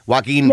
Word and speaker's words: Joaquin: Joaquin yep, Phoenix.